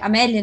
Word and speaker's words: Amélie: É, essa questão do Higgs, na verdade, ele teve esse contato com a, com a Emily é, Amélie, né? Amélie, falei errado.